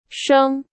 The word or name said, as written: 生